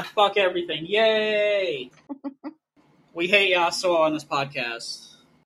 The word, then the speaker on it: Yasuo: And then Yasuo, did I say that right? – Yeah.